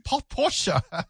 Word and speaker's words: Porsche: Yeah, I mean, talking of that, we always thought it was a Porsche until somebody told us, no, it's not posh to say Porsche. You've got to say Porsche. Porsche. – Got to put the E on for German.